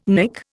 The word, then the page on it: Nick: Indian